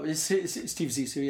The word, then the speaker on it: Zissou: Steve Zissou, yeah.